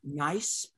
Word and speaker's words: Niise: One is Nice, but they spell it N-I-I-S-E.